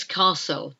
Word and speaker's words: castle: A king and his castle. Castle is spelt C-A-S-T-L-E. Castle. Castle is a silent T.